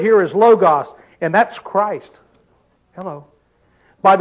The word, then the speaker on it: Logos: Logos is pronounced in the Greek. Logos.